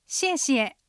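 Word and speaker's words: 谢谢: シエシエ、ありがとう。発音はシエシエ、もしかすると日本語でありがとうと言ってくれるかもしれません。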